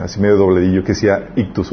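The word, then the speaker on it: ictus (ἰχθύς): la palabra griega se pronuncia ah, ictus. Se pronuncia ictus y significa PES en griego y las iniciales del PES e significa Jesús, Hijo de Dios, Salvador del mundo.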